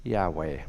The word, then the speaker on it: Yahweh: But then we, to pronounce it, we put in two vowels, an A and an E, and we got Yahweh.